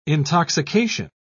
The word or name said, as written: intoxication